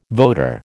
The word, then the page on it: voter: American English